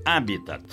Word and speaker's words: habitat: Pronuncie corretamente. Habitat, como proparoxítono, sem esquecer o T mudo final.